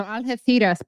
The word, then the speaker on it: Algeciras: Now, Algeciras, by the way, that's how you say it in Spain with the zeta.